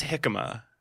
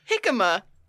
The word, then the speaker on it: jicama: Jicama. – They were giving kids sauerkraut every Tuesday. – It's not pronounced jicama. – Jicama. I think it's jicama.